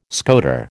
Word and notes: scoter: American English

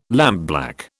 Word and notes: lampblack: British English

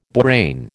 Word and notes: borane: American English

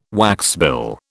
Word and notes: waxbill: British English

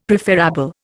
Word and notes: preferabl: Indian

